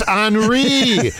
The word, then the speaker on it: Henri: Oh, that's Henri. It's spelled Henry, but it's Henri.